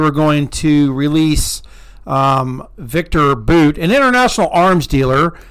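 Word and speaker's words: Bout: So anyways, Brittany Griner and Victor Boot, it's pronounced Boot,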